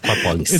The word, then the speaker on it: Aquapolis: Aquapolis. – It's Aquapolis? like Acropolis.